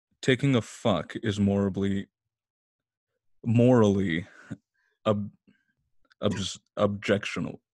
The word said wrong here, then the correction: objectionable: ob, objectionable.